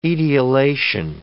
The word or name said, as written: etiolation